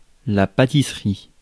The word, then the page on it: pastry: American English; [ˈpeɪs.tɹi]